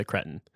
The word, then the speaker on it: cretin: That's how we say it in North American English. We say cretin. We don't say cretin.